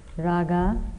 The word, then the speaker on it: raga: Raga, dodha, moha. Raga means sensuous desire. Raga, R-A-G-A, raga. Dodha, D-O-S-A. S is pronounced as TH, no? Ill will. Moha, M-O-H-A, moha.